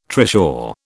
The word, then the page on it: trishaw: British English